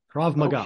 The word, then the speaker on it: Krav Maga: Krav Maga. – Okay. Tell my audience, well, how about tell your host a little about Krav Maga? – Okay, Krav Maga was invented to train the IDF, the Israeli Defense Forces.